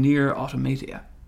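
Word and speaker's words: NieR:Automata: And the first email we have comes in from Jay, and he is asking our opinion on Nier Automata.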